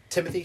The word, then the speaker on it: Timothy: Timothy, T-I-M-O-T-H-Y, Beers, B-E-E-R-S.